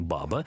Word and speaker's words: баба: Вас тоже, Андрей, кто-то ввел в заблуждение, мягко говоря, потому что слово «баба» в данном случае именно «баба». Ударение только на первый слог.